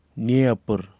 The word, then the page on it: niephirr: Kwamera